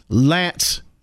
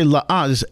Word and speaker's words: L'Anse: This week I'm in Lance, Michigan. I used to say La'anse, it's L'ANSE. And at first I thought it was La'anse, but it's Lance. I guess the way they pronounce it.